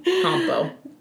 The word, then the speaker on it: Campo: The A is pronounced ah. Campo.